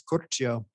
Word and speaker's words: Curcio: In Italian, it's Curcio.